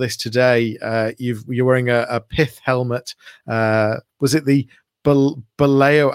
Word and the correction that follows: Bulawayo: I can't pronounce it. Baleo pattern? – Almost. It's a Bulawayo pattern pith helmet.